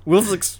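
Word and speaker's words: Willzyx: Woolsey X. Yep, yep. – So Woolsey X, that's W-I-L-L-Z-Y-X, like the South Park episode, The Whale.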